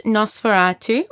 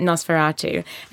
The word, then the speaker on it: NOS4A2: So I chose, crazily, to read a book called, now it's pronounced Nosferatu, but it's actually N-O-S-4-A-2. It's a number plate, and that's how it is on the cover. It's very clever. You pronounce it Nosferatu, and it's the third novel by Joe Hill.